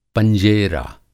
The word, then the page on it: पंजेरा: Hindi